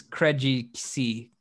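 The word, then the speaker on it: Krejčí: Vit Krejci. – Okay. Krejci. It's Krejci.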